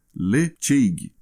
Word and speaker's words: Ledaig: A destilaria mudou de nome, já se chamou Ledaig, mudando para Tobermory. E na caixa deste whisky de 10 anos vem ensinando a pronúncia dele correta, que seria Lecheig, do gaélico.